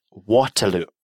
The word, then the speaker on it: Waterloo: Mit Waterloo.